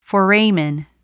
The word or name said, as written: foramen